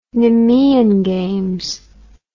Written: Nemean Games

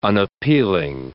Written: unappealing

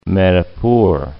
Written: Manipur

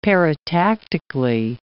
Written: paratactically

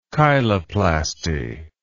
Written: cheiloplasty

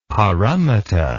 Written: paramita

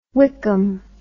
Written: Wykeham

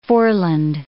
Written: foreland

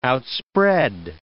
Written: outspread

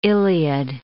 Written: Iliad

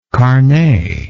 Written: carne

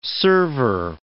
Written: server

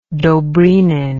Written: dobrynin

